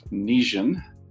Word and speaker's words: Nizhyn: He lives in the western part of Ukraine, in Lviv, and he graduated from the University of Nizhyn. Did I say that right, Igor? – Yes.